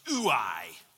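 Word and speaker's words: ooi (οὐαί): It's pronounced ooi.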